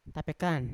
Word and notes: tapekan: Yawa

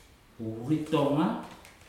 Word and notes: uritoga: futuna aniwa

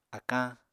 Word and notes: akañ: apatani

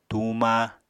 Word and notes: tuuma: apatani

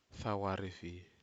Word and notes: fawarefi: Aneityum

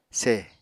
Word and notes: seh: Apatani

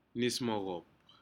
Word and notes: nis mocop: Aneityum

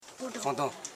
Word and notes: fondon: fon.don

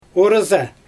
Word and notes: oryzA: Xyzyl

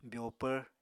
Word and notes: byoopvr: Apatani

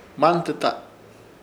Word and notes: Hmantɨta: Ishir Chamacoco

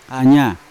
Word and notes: anya: Yawa; Unat

